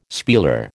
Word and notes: spieler: American English

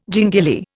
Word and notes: gingili: Indian

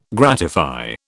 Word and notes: gratify: British English

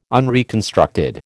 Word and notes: unreconstructed: American English

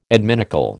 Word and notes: adminicle: American English

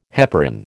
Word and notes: heparin: American English

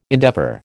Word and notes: endeavour: American English

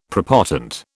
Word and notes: prepotent: British English

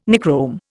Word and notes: Nichrome: Indian

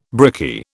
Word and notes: brickie: British English

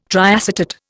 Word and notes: triacetate: Indian

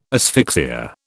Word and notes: asphyxia: British English

